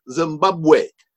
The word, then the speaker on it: Zimbabwe: And when I was doing this story, I didn't know how to pronounce Zimbabwe. And I said, I don't even remember what I said, but I said it wrong. And this sixth or seventh grade boy said, oh, no, it's Zimbabwe.